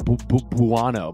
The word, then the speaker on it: Buono: They're just like, who's this Noah Buono? Bueno?